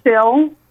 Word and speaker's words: sill: It's spelled S-I-L, okay. – Sill,